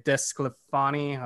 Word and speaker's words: DeSclafani: Kind of reminds me of Desclafani. I butcher his name, but similar to last year, they always seem to get that guy. Cobb is very similar. – You actually nailed his name this time.